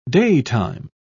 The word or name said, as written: daytime